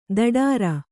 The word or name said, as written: ದಡಾರ